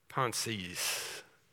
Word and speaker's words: pensées: Blaise Pascal, 1670, wrote a book on his pensées. That's how it's pronounced.